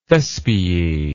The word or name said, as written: thespiae